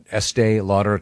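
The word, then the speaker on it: Estée Lauder: Is it pronounced Estee Lauder? – Estee Lauder, yes.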